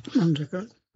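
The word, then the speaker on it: Manjackal: I właśnie tutaj muszę nawiązać do takiego wydarzenia, które miało miejsce na rekolekcjach, które prowadził ojciec z Indii, ojciec James Maniakal. Nie wiem, czy się tego nie wymawia, Manjakal. – Manjakal.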